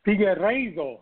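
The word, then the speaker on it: Figueiredo: Just give me quickly, am I saying his name correctly? – Figueredo, yes, sir.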